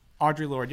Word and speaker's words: Audre Lorde: Audre Lorde, yeah.